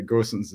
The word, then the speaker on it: Gosens: It's okay if you pronounce it Gosens. It's fine. It's fine. Uh, I, I, Gosens is the, the German pronunciation.